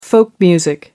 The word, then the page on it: folk music: British English